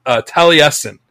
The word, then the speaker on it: Taliesin: but I looked it up and it's pronounced Taliesin, and they're a prog metal band, at least according to the Metal Archives.